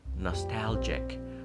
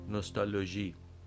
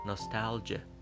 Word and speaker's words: نوستالژی: تلفظ نادرست فارسی نوستالژی تلفظ انگلیسی البته اون چیزی که خیلی غالب هست ناستالژه و تلفظ بهتر فارسی نوستالژی نوستالژی